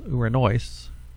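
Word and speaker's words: uranoise (οὐρανοῖς): The next word is uranoise. The noise, the accent is on the diphthong omicron iota in the final syllable, and it's uranoise.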